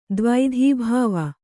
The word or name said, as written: ದ್ವೈಧೀಭಾವ